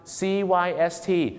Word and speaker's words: cyst: เขาจะออกเสียงว่า cyst นะครับ cyst cyst นะฮะสั้นๆสระเอียดนะครับ cyst ไม่ใช่ seed